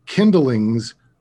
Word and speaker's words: Kindling's: And I should never mention the Kindling's Muse without, I should pronounce this carefully. The Kindling's Muse. That's kindling like firewood.